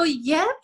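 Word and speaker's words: Giúp: So Yip, it literally means to help in Vietnamese. So it's spelled G-I-U-P, and, you know, it's like to, it's like to help and to serve, you know.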